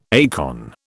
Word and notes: acorn: British English

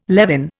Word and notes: levin: Indian

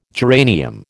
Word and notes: geranium: American English

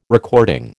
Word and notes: recording: American English

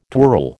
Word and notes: twirl: American English

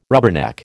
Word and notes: rubberneck: American English

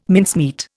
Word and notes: mincemeat: Indian

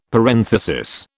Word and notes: parenthesis: British English

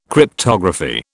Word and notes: Cryptography: British English

